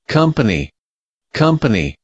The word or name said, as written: company